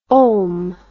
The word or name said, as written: olm